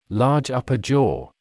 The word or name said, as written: large upper jaw